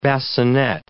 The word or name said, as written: bassinet